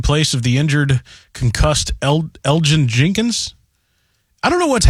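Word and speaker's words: Elgton: Elgin Jenkins. – Elgin Jenkins, much like Elton John.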